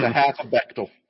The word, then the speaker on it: Bechdel: The Bechdel test,